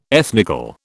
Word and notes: ethnical: British English

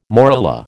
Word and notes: morula: American English

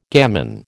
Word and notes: gamin: American English

